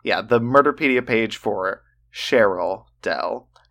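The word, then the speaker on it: Cherylle: Also, her name is pronounced Cheryl. It's spelled Cherry, L-L-E.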